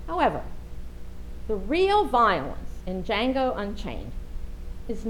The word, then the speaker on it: Django: Yes, I saw Django Unchained. Anybody else see it? Anybody admit to it? The D is silent.